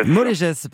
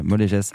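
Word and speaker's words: Mollégès: Vous êtes agriculteur, producteur de pommes à Molège, c'est ça, je le prononce bien ? – Molégès, pardon. Mais oui, voilà, Molégès.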